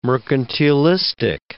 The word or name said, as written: mercantilistic